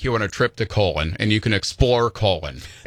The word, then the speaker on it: Colon: They literally pronounce it Colon, and it's the magic capital of the world.